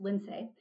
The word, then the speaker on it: Lindsay: So my parents tried to get creative with my name. So it's actually pronounced Lindsay, but call me Linds.